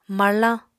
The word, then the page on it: marlañ: apatani